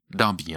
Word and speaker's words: Derby: Continuons vers l'est en direction de Nottingham et arrêtons-nous à Derby. Darby, hein, vous notez la prononciation.